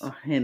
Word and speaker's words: Argennis: Pronounce it as an H. – Yes, that's all you need. – Yeah, the G throws me off. So Arjenas. – Mm-hmm. Um, Arjenes. – Arjenes.